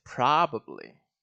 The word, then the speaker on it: probably: I think it's probably, probably, probably, probably, ah, very curious, it's probably, probably, probably, probably. – All this time I was saying probably, but it's not probably, it's probably, pra.